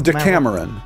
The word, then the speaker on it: Decameron: So wait, it is Deca Maroon is the name of the... – Decameron. No, it's spelled like Cameron, James Cameron, gotcha. With D-E in front of it.